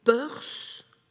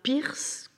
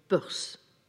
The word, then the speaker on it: Peirce: Je précise, il faut prononcer Peirce et non pas Pierce comme l'héroïne du roman de James Kane. Donc Peirce.